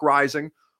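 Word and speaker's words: Reising: at Buck Rising, R-E-I-S-I-N-G, I will answer all of your questions.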